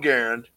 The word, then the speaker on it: Garand: So when I grew up, my father had an M1 Garand. And yes, for those wrinkling your fucking nose, that's how you pronounce it, because John Garand invented it.